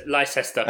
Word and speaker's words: Leicester: Leicester. – And Jeff, this is more one for me and you, Chuck, but Jeff has said it's brilliant that the captions turn Leicester into Leicester as if we're talking about the jockey Leicester Piggott.